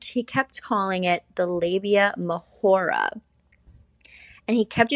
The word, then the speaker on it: labia majora: And eventually, like at the very end of the presentation, the professor chimed in and was like, just letting you know, it's pronounced labia majora. Turns out this guy thinks that it is in Spanish, hence the Mahora.